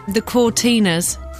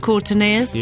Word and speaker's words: Cortinas: There's one word which annoyed me for about 6 months when I worked here, because when I saw it written down, I couldn't pronounce it properly. Do you remember there was a band called the Cortinas? – Oh, yeah. – But every time I saw it written down, I had to say the Cortineers.